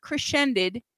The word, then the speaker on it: Crecended: This is a band that I'm going to pronounce it Crescended. Even though it is spelled C-R-E-C-E-N-D-E-D, there's no S in there, but I'm still going to call it Crescended.